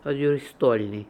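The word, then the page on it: Oduristolnian: Russian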